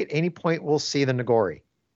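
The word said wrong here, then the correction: Noghri: I don't think we'll see the Nogri here. – Okay. It's Nogri, because I've always pronounced it Nogori, but whichever.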